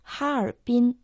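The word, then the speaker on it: Харбин (哈尔滨): Название города Харбин на китайском языке звучит почти так же, как на русском. Оно состоит из трех иероглифов «Харбин». Первый иероглиф «ха» произносится в первом тоне.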